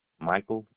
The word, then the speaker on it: Michael: Michael, M-I-C-H-A-E-L. Donaldson, D-O-N-A-L-D-S-O-N. – You said Michael, M-I-C-H-A-L-E. – A-E-L. – A-E-L, I'm sorry.